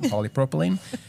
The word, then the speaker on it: polypropylene: It's taken me a while to learn how to say it, polypropylene.